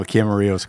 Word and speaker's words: Camarillo: Did I say that right? Or do you guys say Camarillo? – No, Camarillo's correct.